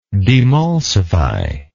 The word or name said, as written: demulsifier